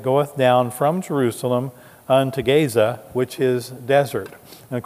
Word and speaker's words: Gaza: Let's take a look at Philip's ministry now going down into Gaza, or as we pronounce it today, Gaza.